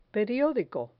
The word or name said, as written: periodico